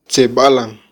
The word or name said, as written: tzebalan